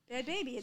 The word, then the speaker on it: Bhad Bhabie: Bhad Bhabie, the H is silent.